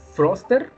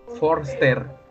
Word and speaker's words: Forster: Primero vamos a hablar de la película de Guerra Mundial Z, la película del 2009, dirigida por Mark Froster. Forster.